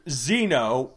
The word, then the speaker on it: Xzeno: One of the villains was named Xeno, which is spelled X-Z-E-N-O. – X-Z-E-N-O. – It's like, okay, there's Xeno like xenophobic, or there's Xeno like that same way but with a Z. Why not combine them and make them silent, each other silent, so it's pronounced the exact same way, but it's fancier looking.